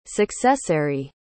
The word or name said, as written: successary